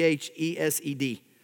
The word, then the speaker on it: hesed: You spell that C H E S E D. We pronounce it hesed.